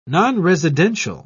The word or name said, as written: nonresidential